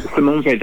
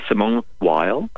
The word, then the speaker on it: Simone Weil: Simone Weil, that's how it's pronounced in French. But many people say Simone Weil.